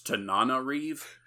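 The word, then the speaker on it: Tananarive: Or I said Tanana, but it's Tanana Reeve.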